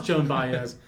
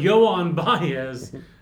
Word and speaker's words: Joan Baez: I know it's not pronounced Joan Baez. But Yoan Baez, whose name is spelled Joan Baez.